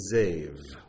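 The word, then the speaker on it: zev (זאב): The Hebrew word for wolf is zev, zev. You would spell it Z-A, and it would be a B, but it's pronounced like a V, zev.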